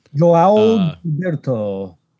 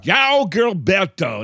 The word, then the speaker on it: João Gilberto: João Gilberto. – Yeah. – João Gilberto.